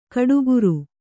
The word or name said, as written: ಗುಟ್ಟುಗಾರ